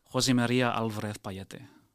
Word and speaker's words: José María Álvarez Pallete: José María Álvarez Pallete. – That is rather wonderfully. I can only assume you speak Spanish excellent pronunciation.